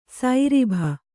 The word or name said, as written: ಸೈರಿಭ